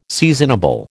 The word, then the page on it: seasonable: American English